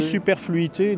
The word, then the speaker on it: superfluité: Ça se prononce comme ça, fluité? Oui, superfluité.